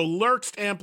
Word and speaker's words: Lurkst: Is it Lurkst? Am I saying it right? – That's perfect. – Okay, so Lurkst amplifiers, and you partnered with Mojo Tone, and you've created this new line of gear.